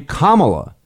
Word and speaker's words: Kamala: She pronounced her own first name Kamala, like the word comma with la.